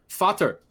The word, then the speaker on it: Vater: Yeah, Vater. It's spelled V-A-T-E-R in German, because V's are pronounced with F's, so it's Vater.